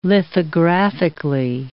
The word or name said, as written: lithographically